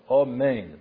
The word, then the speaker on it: Amen: Ela yikra alf bekametz gadol. Amen. That's how it's pronounced.